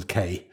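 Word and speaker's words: Cay: So in the same way as we have, I don't know, John's Island, it would be John's Kay.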